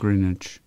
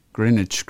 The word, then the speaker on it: Greenwich: Da ist nämlich ein W drin und das spricht man ja, nicht. Ja, Greenwich. Greenwich, Greenwich Mean Time.